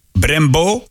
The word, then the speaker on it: Brembo: Brembo, zo zal het daar uitgesproken worden.